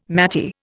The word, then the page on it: maty: Indian